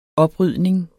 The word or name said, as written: oprydning